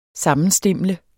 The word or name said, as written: sammenstimle